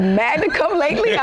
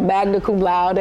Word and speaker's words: magna cum laude: I could never pronounce it correctly. So I said, magna cum lately, honest. No, magna cum laude.